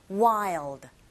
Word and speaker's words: wild: Goes 한 다음에 wild 하지 마시고 wild. L 사운드 발음 내실 때 L 사운드는 혀끝이 위로 올라가서 뒷니에 붙으셔야 돼요. 그래서 wild가 아니라 첫 번째 아 이 부분에 강세 주셔서 wild. 이렇게 하시면 됩니다.